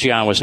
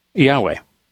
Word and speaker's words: Yahweh: Now, I'm going to tell you this other thing that's here that's important, where this is Yahweh's name.